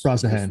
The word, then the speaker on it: Brosnahan: She mentioned in that speech, she mentioned Rachel, how do you say, Brosnahan from Miss Brosnahan, Maisel, correct, yeah.